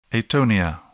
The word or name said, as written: atonia